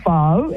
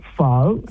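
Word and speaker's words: phở: It's pho. It's like really deep in your throat, pho. And you've got to say it with love, right? Pho.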